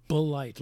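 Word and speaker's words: Bulleit: Blight. Like bullet, but with an I snuck in there.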